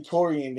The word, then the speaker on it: Taurrian: my name Taurrian, they ain't know how to pronounce how like the name, they'll be like T.